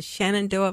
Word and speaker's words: Shenandoah: Now, I hope I say it correctly, Robert, in the Shenandoah Valley? – That's correct, yes.